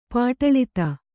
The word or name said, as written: ಪಾಟಲಿತ